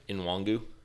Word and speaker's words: Nwangwu: But how do you say his last name again? – Nwangu. – It's an N-W. – N-W-A-N-G-W-U.